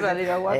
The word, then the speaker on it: Badiraguato: De que el alcalde de Varidahuato, Badiraguato, lo dije bien, ¿no? – Sí, Badiraguato.